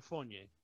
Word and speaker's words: Fournier: Evan Fournier.